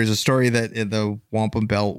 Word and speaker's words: wampum: But there is like, we, you know, all jokes aside with the Wanap Belt, is it the Wanap Belt? How do I say it again? – The Wampum.